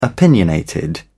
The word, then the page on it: opinionated: British English